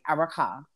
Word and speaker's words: Erica: So another news that happened recently, like yesterday or the day before that Safari and Erica, Love and Hip Hop stars, Erica started the Love and Hip Hop. – No, you gotta say her name right. Araka.